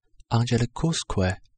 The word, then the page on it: angelicusque: Latin